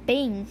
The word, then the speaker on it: being: 他原本念 being。 being 呢，也就是常见的台湾人可以犯的错哦。为什么呢？它会变成是 b i n g 的发音，可是这个字呢，它其实是 b e i n g。